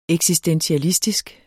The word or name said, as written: eksistentialistisk